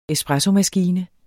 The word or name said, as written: espressomaskine